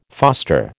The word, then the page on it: foster: American English